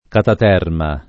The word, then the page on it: cataterma: Italian